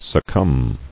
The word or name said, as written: Succumbed